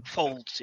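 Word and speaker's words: faulds: Going back to the rest of the armor, we have the Phoenix, is that Folds? I'm not sure how to pronounce that one. – Folds, yeah. Yeah, it's a term for waist armor.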